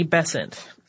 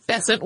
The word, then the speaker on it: Besant: In 1888, the Bryant and May match factories came to the attention of Annie Besant. Most American pronunciations of this seem to rhyme with Crescent, so we're going with that.